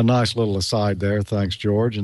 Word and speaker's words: Jorge: His name is George, like mine, except it's spelled J-O-R-G-E, but it's pronounced George in English. And his last name, and I'm not sure I can pronounce this correctly, it's C-O-M-A-S, and so maybe that's Comas.